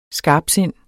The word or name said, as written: skarpsind